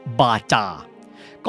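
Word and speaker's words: Baťa: บาจาก่อนอื่นเลยครับหลายคนถามว่าเขียนว่าบาทาทำไมจึงอ่านว่าบาจานะครับไปเช็คมานะครับก็คือชาวเช็กเองเขาอ่านออกเสียงคำว่า BATA ว่าบาจา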